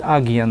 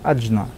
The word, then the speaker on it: Аджна: Все знают, что такое Муладхара, Сватхистана, Манипура, Анахата, Вишудха, Аджна. Ее иногда называют Агья, но это неправильно. На санскрите, если говорить о правильном написании, то читается как Аджна и Сахасрара.